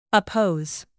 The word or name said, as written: oppose